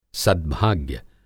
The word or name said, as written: सद्भाग्य